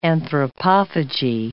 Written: anthropophagy

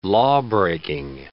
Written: lawbreaking

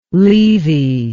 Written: leavy

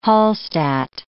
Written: Hallstadt

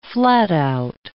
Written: flat-out

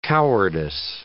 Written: cowardice

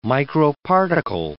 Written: microparticle

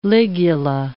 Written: ligula

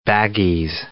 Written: baggies

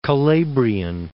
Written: Calabrian

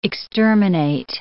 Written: exterminate

